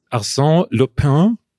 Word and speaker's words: Arsène Lupin: Arsène Lupin. Ich glaube, so spricht man es im Französischen aus.